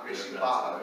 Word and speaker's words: Barra: And I think I asked you and you were saying that particular one that you're involved with, Gracie Barra? – Baja. – Baja, Gracie Baja. – R's. – What is it? – They don't pronounce R's in Portuguese.